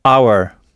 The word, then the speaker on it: hour: Mais il y a d'autres mots qui commencent par un H pour lesquels ce H ne doit pas être prononcé. Il doit donc être muet, comme le H français. C'est le cas, par exemple, de hour, H-O-U-R, heure.